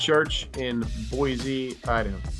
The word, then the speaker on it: Boise: You're saying Boise right. You did it. Hey, there is a little bit of your Eastern. The Z slips in at times, but you're close. Like you're really close to saying it right.